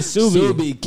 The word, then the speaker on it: Ksubi: Subi. K-S-U-B-I. – Yeah, people pronounce it Kasubi because, nah, it's Subi.